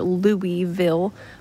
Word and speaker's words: Louisville: Anything that has a vill at the end, they say vull, like Louisville, Kentucky. It looks like Louisville or Louisville, but Louisville, I don't know who came up with that.